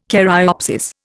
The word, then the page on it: caryopsis: Indian